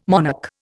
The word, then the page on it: monarch: Indian